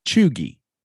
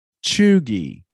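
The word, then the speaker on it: cheugy: So the first word I've got is chuggy. Chuggy. And it's spelled C-H-E-U-G-Y. C-H-E-U-G-Y.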